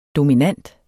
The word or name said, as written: dominant